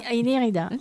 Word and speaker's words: Inirandirida: Alors, redonnez-nous son nom parce que je l'ai écorché. Inirandirida, c'est ça ? – Inirandirida, oui.